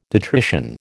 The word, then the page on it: detrition: American English